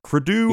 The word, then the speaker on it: Crudup: Crudup.